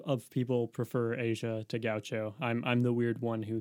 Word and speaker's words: Gaucho: I pronounced it correctly. – That's Gaucho. Yeah.